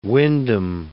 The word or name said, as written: Wyndham